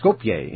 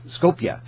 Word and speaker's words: Skopje: Skopje. – Skopje, Macedonia. – It's spelled Skopje. So I was saying Skopje, or something like that.